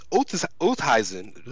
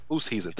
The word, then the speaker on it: Oosthuizen: Louis Oosthuizen, Louis, I, I never said his name right. Fifty to one. Louis Louis Oosthuizen. – Oosthuizen. Thank you, thank you, because he's South African, right? – Yep.